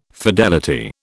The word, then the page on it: fidelity: British English